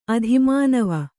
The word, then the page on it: ಆದಿಮಾನವ: ādimānava